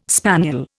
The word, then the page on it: spaniel: Indian